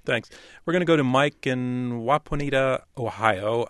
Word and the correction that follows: Wapakoneta: I'm concerned, Mike, that I've mispronounced the name of your hometown. – Hi. – Where are you? Where are you located? – I'm in Wapakoneta, Ohio. – Wapakoneta, okay.